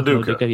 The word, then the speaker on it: LoDuca: LoDuca, yeah.